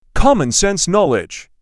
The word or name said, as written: Commonsense knowledge